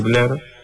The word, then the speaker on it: Blur: La banda se llama, se escribe Blur, se dice Blur, y el tema es Café y Televisión, Coffee and TV.